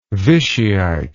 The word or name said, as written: Vichyite